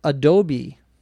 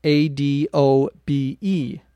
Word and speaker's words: Adobe: There is also a company, internet company, software company, that makes uh, many different types of software, and it's called Adobe, A D O B E. Once again, the E at the end is pronounced. We don't say Adobe.